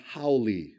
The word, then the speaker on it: haole: And that word is pronounced haole.